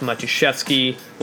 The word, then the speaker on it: Matuszewski: It's all at Raf Matuszewski, long last name. It's spelled M-A-T-U-S-Z-E-W-S-K-I.